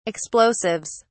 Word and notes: explosives: British English

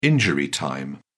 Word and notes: injury time: British English

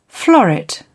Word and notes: floret: British English

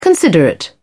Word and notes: considerate: British English

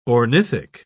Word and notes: ornithic: British English

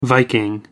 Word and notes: viking: American English

